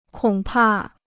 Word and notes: 恐怕: kǒng pà

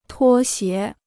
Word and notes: 拖鞋: tuō xié

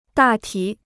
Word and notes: 大体: dà tǐ

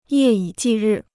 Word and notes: 夜以继日: yè yǐ jì rì

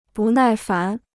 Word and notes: 不耐烦: bù nài fán

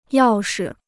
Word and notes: 要是: yào shi

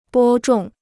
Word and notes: 播种: bō zhòng

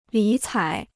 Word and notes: 理睬: lǐ cǎi

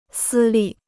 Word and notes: 私立: sī lì

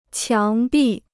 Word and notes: 墙壁: qiáng bì

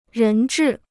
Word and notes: 人质: rén zhì

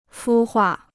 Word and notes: 孵化: fū huà

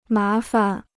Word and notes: 麻烦: má fan

